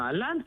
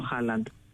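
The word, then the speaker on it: Haaland: Βρε τον, ποιος είναι, και λέγανε Αλάντ, βρε ποιος είναι ο Αλάντ, και μετά καλωδίωσα μετά από την επόμενη μέρα ότι ήταν ο Χάλαντ. Αλλά έτσι τον προφέρουν οι Γάλλοι.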